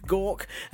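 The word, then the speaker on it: Gauke: Back in the UK, Justice Secretary David, pronounce it like you're being sick, gawk,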